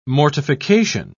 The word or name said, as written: mortification